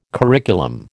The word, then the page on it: curriculum: American English